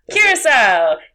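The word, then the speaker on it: Curaçao: Which was in, and I don't know if I'm going to pronounce this correctly, Curacao. – As in Blue Curacao? – Curacao!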